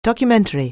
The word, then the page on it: documentary: anglais